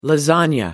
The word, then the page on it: lasagna: British English